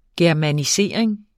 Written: germanisering

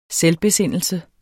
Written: selvbesindelse

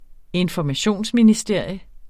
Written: informationsministerium

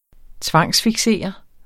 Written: tvangsfiksere

